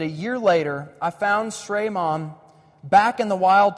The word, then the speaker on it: Srey: One, I'm going to butcher the name, it's S-R-E-Y, Srey, Srey Mom.